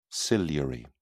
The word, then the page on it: ciliary: British English